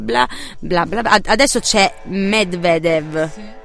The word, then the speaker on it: Medvedev: Eh, eh, più o meno, io ho detto Medvedev, lei no, non, sì, hai detto giusto, tutto. più o meno, si dice Medvedev.